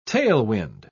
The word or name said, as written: tailwind